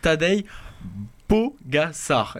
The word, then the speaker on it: Tadej Pogačar: On va parler de Vuelta, on va parler de vélo, et on va parler de Tadej Pogačar. Est-ce que c'est bien prononcé? – C'est parfait, vous êtes aussi bon en slovène qu'en anglais.